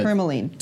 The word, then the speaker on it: tourmaline: Tourmaline.